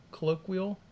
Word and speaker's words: colloquial: No one's I think you said it right. – Colloquial? Colloquial? Colloquial? – It's quill. – it's quill? Colloquial.